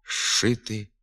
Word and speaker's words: зшити: На письмі «зшити», «зчистити», а у вимові «шити», «щистити».